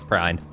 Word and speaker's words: Prine: I thought it was Prine. – Yeah, you're right.